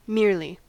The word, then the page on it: merely: American English